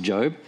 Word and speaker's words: Job: Well, we're tonight beginning a new series in this Old Testament book called Job, spelt like Job, but pronounced Job.